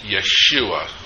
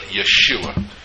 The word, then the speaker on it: Yeshua: It's Yeshua, which we would call Joshua, but Yeshua. Y-E-S-H-U-A is the way we would probably spell it if we were going to do this.